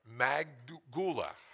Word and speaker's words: Magdala: Magdala.